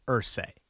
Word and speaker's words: Irsay: And how do you say his name? Irsay or Irsay? – I've heard it pronounced both, but I believe it's Irsay, yes.